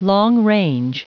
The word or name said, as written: long-range